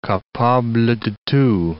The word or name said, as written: capable de tout